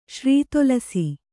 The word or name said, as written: ಶ್ರಿತುಲಸಿ